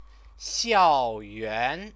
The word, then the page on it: xiaoyuan: Chinese